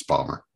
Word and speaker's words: Baltimore: Ballmer. Sorry. I live here. You got to say it right. It's Ballmer.